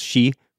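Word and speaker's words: she (四): Four is a really unlucky number in Japan because it's pronounced it can be pronounced she, which is the same as death so since the Taira were defeated by the Minamotos, this is you know symbolizing the death of the Taira clan.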